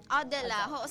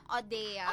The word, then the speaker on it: Odella: Odella. So, una, tawag ko pa na Odea. – Odea. Abi ko gid mag-pronounce na kay Odea.